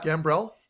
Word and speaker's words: Gambrell: Yeah, Gambrell.